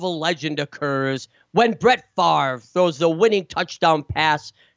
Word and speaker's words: Favre: You know, we still had to learn how to pronounce his name. Brett Favre, Favre.